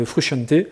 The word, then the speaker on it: Frusciante: Alors on va se mettre d'accord tout de suite. Oui, on prononce Frusciante.